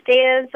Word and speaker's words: Deya: Hi, my name is Deya Zavala.